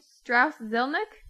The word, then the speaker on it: Strauss Zelnick: So Take-Two chairman Strauss Zelnick.